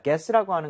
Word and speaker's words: guess: 자, guess라고 하는 것은 추측하다, 맞춰보다라는 뜻입니다. 여기서는 어떻게 보면은 you가 묵음이라고 생각을 해야 돼요. 그래서 구에 이렇게 가지가 않죠. 무조건 guess. – Yes. – guess가 맞는 발음이고요.